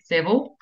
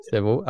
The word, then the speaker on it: Seville: Locals say Seville. Yeah. – Seville.